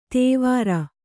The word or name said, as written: ದೇವರ